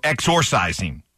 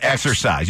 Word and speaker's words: exorcising: Yeah, it's exor. – Exorcised? – Exorcised. So not exercise. You don't exercise.